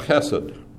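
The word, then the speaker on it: hesed (חֶסֶד): It's a Hebrew word that's pronounced hesed, and it appears in this repeated phrase throughout the psalm, which makes it probably the most important word that's in the psalm.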